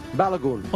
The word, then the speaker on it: Balogun: Balogun.